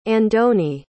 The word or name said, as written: andoni